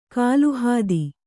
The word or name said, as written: ಕಲ್ಹತ್ತಿ